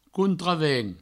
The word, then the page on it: contravent: countravent